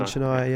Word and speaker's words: Chennai: And also one in Chennai, I think is how you say that one. So In Chennai, yeah.